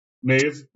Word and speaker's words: Maeve: Queen Maya, is it Maev? Is that how they pronounce that? – Maeve.